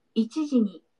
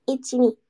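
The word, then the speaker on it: Ichiji (いちじ): Ichijini.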